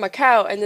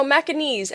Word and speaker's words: Macanese: When like you introduce yourself here in America, do you ever when you say like yeah I'm from Macau, and then they're gonna be like. How do you say people from Macau? Macauan? And then I'm like, no, Macanese.